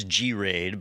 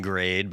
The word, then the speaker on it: Graid: So I thought it was G-RAID, but I could see it being grade, but it's spelled G-R-A-I-D. And the reason I think it's probably G-RAID is this is a company that's focused on RAID controller technology.